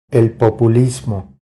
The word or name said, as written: El populismo